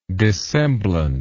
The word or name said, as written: dissemblance